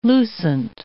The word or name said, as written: lucent